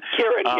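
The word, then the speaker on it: Ciarán: I guess Kieran is how you say his name. – Kieran, yes. – It looks like Ciaran Hines, but Kieran Hines.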